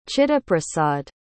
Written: chittaprasad

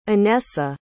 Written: anessa

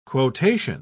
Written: quotation

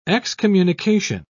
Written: excommunication

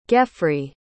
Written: geffrey

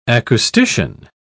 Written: acoustician

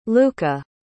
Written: Luka

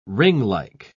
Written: ringlike

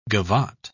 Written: gavotte

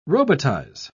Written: robotize

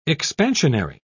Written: expansionary

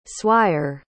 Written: swire

